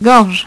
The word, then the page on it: gorge: French